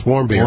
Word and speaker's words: Warmbier: You know, I'm listening to, at this point in time, I'm multitasking here, the story of this kid Michael Warmbier, is that how you pronounce his name? Oh, yes, Warmbier.